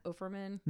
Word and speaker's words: Offerman: Or am I saying that wrong? Offerman? – No, you're saying it right.